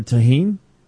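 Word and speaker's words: Tajín: So what you got to do to avoid the ice is just ask for tahine. – I don't know what that is. – Tahine is a it's a seasoned salt. It's not actually salt. It's spelled T-A-J-I-N.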